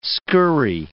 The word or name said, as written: scurry